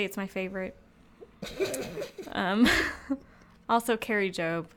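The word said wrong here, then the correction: Kari Jobe: Carrie Joby.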